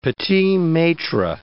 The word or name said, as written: petit-maitre